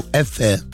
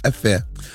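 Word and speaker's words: Өфө: Мы были недавно, последний раз мы были в Уфе. Эфе. – Mm-hmm. – Правильно говорит? Эфе. – Это вот. Среди кого вы так правильно говорите? – А это среди уфимцев.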